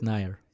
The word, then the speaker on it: Nair: Rohit Nair.